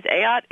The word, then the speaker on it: Ayotte: Actually, my last name's Ayotte. It's a boat, not a yacht. It's a yacht, not a boat, isn't it? – It's a yacht.